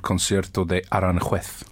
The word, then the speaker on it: Concierto de Aranjuez: And it's called Concierto de Aranjuez, I yes.